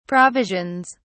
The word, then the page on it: provisions: British English